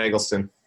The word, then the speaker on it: Eggleston: Yes, Tyler Eggleston.